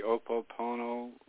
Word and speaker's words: Ho'oponopono: I'm not sure if I'm pronouncing it correctly, but do you know the Ho'oponopono prayer? Am I saying that correctly? – Mm-hmm.